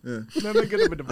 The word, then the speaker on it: Nurmagomedov: Yeah, Nurmagomedov. – Nurmagomedov.